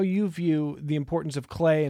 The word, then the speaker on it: CLAH: And I want to spend some time on this concept that Joel just mentioned called, pronounced CLAH, C-L-A-H. So you often hear about STEM, science, technology, engineering, math. CLAH is C-L-A-H, culture, language, arts, and humanities.